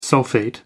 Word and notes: sulphate: American English

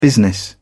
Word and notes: business: British English

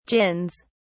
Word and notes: gins: British English

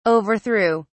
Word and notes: overthrew: British English

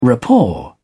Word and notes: rapport: British English

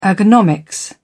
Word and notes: ergonomics: British English

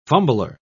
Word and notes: fumbler: British English

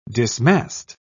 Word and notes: dismast: British English

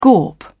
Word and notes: gawp: British English

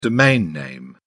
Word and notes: domain name: British English

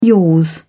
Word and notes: yours: English; British English

